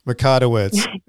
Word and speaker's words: Mikotowicz: Mikotowicz.